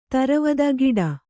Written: ತರವದ ಗಿಡ

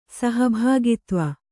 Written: ಸಹಭಾಗಿತ್ವ

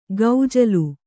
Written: ಗೌಜಲು

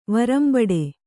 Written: ವರಂಬಡೆ

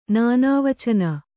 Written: ನಾನಾವಚನ

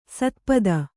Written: ಸ್ಥಪತಿ

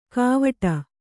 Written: ಕಾವಟ

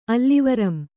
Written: ಅಲ್ಲಿವರಂ

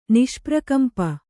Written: ನಿಷ್ಪ್ರಕಂಪ

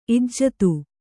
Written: ಇಜ್ಜತೆ

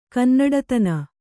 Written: ಕನ್ನಡತನ

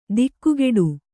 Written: ದಿಗ್ಗೇಡಿ

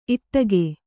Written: ಈಡಿಗ

